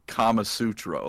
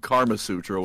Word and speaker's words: Kama Sutra: And Andy, bringing it up strong with Eldar version of the Kama Sutra. Well, he said Karma Sutra, which is not how you spell it or say it.